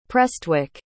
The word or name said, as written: Prestwick